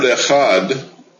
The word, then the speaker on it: echad: For that reason, in the word echad, which means one. The pronunciation of the two letters Ket and Dalet is lengthened.